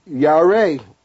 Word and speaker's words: yare: So that they that fear you, that's that word that we dealt with when we talk about in the word picture, yare, fear the hand. – Fear is the word? – Yare. – Okay. – We would spell it Y-A-R-E or Y-A-W-R-A-Y.